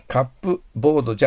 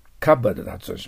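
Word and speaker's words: cupboard: カップボードじゃないです。カバードで発音します。日本語的に書くと、カタカナで書くとカバードです。カバード、これはカップボードが速くなって P が聞こえなくなったんです。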